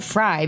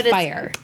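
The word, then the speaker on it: Fyre: I always call it fry because it's spelled like fry boots, but it's fire. Fire.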